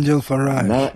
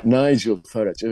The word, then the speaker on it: Nigel Farage: İngiltere'de de Nigel Farage, onu da mı yanlış telaffuz ettim bilmiyorum. – Nigel Farage. – Nigel Farage, evet.